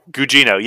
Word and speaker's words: Gugino: Gugino, yes.